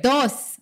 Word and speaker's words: dos: Did I say that right? – Yes. Correct. – Dos.